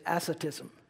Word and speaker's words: asceticism: That is called something, it has a name, and it's asceticism.